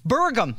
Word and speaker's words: Burgum: Doug Bergman? Berman? How do we say his name? That's how lost cause he is. – Burgum. Yeah.